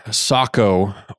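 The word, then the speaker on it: Sako: For those that aren't aware, Socko, or as Americans pronounce it, Seiko, is the same company.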